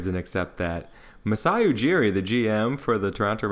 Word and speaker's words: Masai Ujiri: Masai Ujiri.